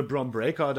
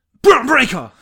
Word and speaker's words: Bron Breakker: Solange am Ende Bron Breakker da als Sieger aus dem Stable hervorgeht, ist alles cool. – Ja, du hast das falsch gesagt. – Bron Breakker! – Ja!